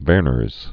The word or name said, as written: Werner's syndrome